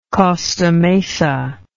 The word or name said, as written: costa mesa